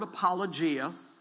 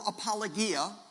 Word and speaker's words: apologia: Well, the word apologia, or apologia, which may be the more proper pronunciation, comes from apo.